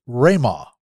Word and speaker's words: Rhema: I believe it's pronounced Rhema. It's spelled R H E M A.